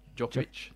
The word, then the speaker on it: Djokovic: Djokovic made. – Novak who? – Djokovic. – Djokovic. – Did sure. – I went with a hard C there, didn't I?